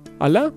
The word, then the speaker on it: Allah: Blues for Ayla. No sé cómo se dice Allah en inglés. ¿Es Ayla o Allah? – Allah. – Allah.